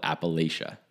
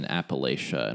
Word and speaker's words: Appalachia: So pro tip, if you want to sound like you're from here, it's Appalachia. No, Appalachia.